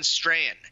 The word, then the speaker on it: Strachan: I just was at a conference with Owen Strahan, whom you know. It looks like it's spelled Strachan, Owen Strachan.